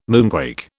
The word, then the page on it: moonquake: British English